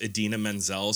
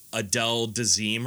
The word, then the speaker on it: Idina Menzel: and he didn't know how to pronounce Idina Menzel, so he, like, called her, like, Adele Dazeem or something like that. And now, Adele Dazeem.